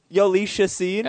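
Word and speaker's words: Jhoulys Chacín: Arisabal Arubu N-A-A. – You nailed it, dude. – Yeah. – The Rockies pitcher, J-H-O-U. – Oh, Julius Chacin? – Yolish Chacin. – All right. – That works. Cardinals third base coach, Jose O-Q-U-E.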